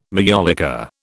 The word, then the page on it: majolica: British English